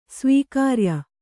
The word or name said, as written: ಸ್ವಕಾರ್ಯ